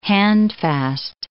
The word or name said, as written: handfast